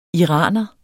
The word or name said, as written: iraner